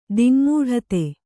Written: ದಿಙ್ಮೂಢತೆ

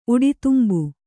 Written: ಉಡಿತುಂಬು

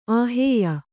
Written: ಆಹೇಯ

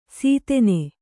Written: ಸೀತೆನೆ

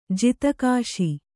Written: ಜಾತಿಕೋಶ

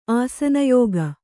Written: ಆಸನಯೋಗ

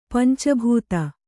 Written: ಪಂಚಭೂತ

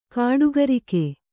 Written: ಗುಡಿಗಾರಿಕೆ

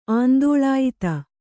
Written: ಆಂದೋಳಾಯಿತ

